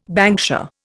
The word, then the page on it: banksia: Indian